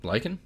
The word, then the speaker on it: lichen: I've read it. L-I-C-H-E-N. – Lichen? – Is that lichen? – Yeah.